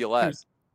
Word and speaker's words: LaViolette: He's guilty of maybe misjudging, and I'm gonna get the name wrong because I used to coach a kid named Marlon LaViolette, and his name is spelled exactly like Jason. LaViolette. – Yes. Thank you.